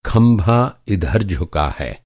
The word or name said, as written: झुकना